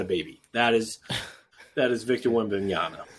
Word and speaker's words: Wembanyama: Am I saying his name right? – Wembanyama. – Yeah, Wembanyama.